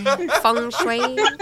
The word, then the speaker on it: feng shui: Is that how you say it? Feng shui? – Feng shui.